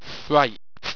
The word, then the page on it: fryped: Owstwecian